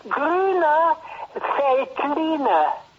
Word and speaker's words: Grüner Veltliner: Grüner Veltliner. Es una V que se pronuncia como F.